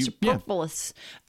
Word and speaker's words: superfluous: we're saying yes to all the other superfluous stuff. Did I say that right? – You, superfluous. – Yeah.